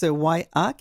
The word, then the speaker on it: WIAIH: Elle, Lynn Charlebois, est directrice de YIA, qui s'écrit W-I-A-I-H, mais qui se prononce YIA, qui est un organisme dont l'acronyme en anglais signifie Association de l'Ouest de l'Île pour les handicapés intellectuels.